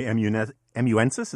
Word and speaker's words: amanuensis: Jack Butler, my amanuensis, is that how you pronounce it? – Yes, I believe so.